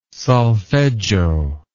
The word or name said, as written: solfeggio